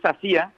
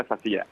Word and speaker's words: Sasia: Es Sacía, es un apellido de País Vasco, pero como no van acentos, se pronuncia Sacía.